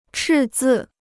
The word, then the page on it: 赤字: chì zì